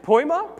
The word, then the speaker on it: poiema: The Greek word here is, I'm looking at Pastor Mike here, poiema? Yeah, you know how it's pronounced. Poima? Yeah, that one.